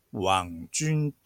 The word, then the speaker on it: 網軍: 中国語ではこれをワン、ジュンと発音します。